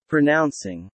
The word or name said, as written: pronouncing